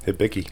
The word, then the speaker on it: Hibiki: Hibiki.